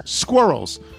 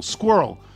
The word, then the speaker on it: squirrel: I grew up in New Jersey and say it in one quick syllable, squirrel, whereas you say it in two syllables, squirrel.